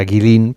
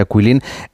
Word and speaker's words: Guilin: Bueno, esta ciudad de Quilín, por si alguien la busca en internet, se escribe Guilin, como si fuera Guilin, pero se pronuncia Quilín.